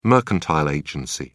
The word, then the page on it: mercantile agency: British English